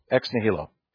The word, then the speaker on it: ex nihilo: How do you pronounce it, Brian? With the H or without? Okay. Yeah, I found both. So for some reason, I like pronouncing the H. So ex nihilo.